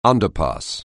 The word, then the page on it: underpass: anglais